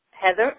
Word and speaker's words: Heather: You can find us on Love Letter to My Sisters and, again, under Heather Lee. – Okay. And can you spell that slowly for our listeners? – My name? – Yes. – Is Heather, H-E-A-T-H-E-R, and the last name is Lee, L-E-E, and Love Letter to My Sisters is the name of my book.